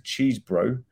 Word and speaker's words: Cheesebro: The letter was written by Kenneth Cheesebro, great name, that's how you pronounce it, So he's a cheese bro,